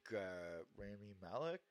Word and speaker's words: Rami Malek: Although I do think Rami Malek, am I pronouncing his name right? – Yeah.